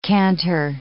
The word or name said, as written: cantor